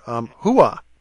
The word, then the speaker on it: hooah: Hooah. Uh-huh. H-O-O-A-H.